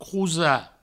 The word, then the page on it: crosar: crousa